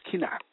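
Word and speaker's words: kinah (קִנְאָה): The Hebrew word for jealousy is kinah, pronounced kinah, meaning jealousy or envy.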